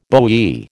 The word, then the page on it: bowie: American English